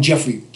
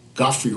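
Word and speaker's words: Geoffrey: And his wonderful wife, we all call him Jeffrey Holder. – Right. – Godfrey Holder, that's his real pronunciation.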